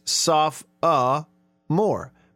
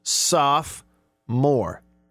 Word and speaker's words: sophomore: Soph, S-O-P-H, O, and then more, M-O-R-E. S-O-P-H-O-M-O-R-E. If you pronounce all the O's, it's soph-a-more. But then the actual pronunciation that is given, if you look it up online, is two syllables. Soph-more. So the middle O would be silent.